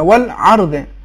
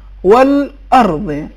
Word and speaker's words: والارض: آیت نمبر 12 اس میں حمزے کا تلفظ عین جیسا ہو گیا والارض, جبکہ صحیح تلفظ یہ ہے والارض.